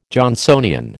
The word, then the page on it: Johnsonian: American English